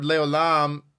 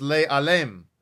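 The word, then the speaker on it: Leolam (לעולם): But the sages point out that the word Leolam is missing, or spelled rather, without the customary Vav. So therefore, it can be pronounced Le'alem, which means to conceal.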